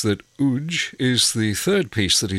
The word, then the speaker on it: UWJ: Spelt UWJ and pronounced UJ, the following piece was written by Colin Spears for viola and piano in 1991.